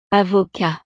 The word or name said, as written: avocat